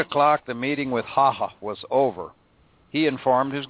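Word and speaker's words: Hácha: Now, that's Emil, and his last name spelled H-A-C-H-A. The Czechs around here tell me that pronunciation is Haha.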